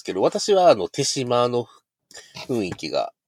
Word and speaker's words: 豊島: これ、て、そう、あの、豊島、豊島と書いて手島って読むんですよ、これ。